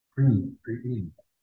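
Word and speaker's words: Priene: And now I'm in Preen, that's how you say it.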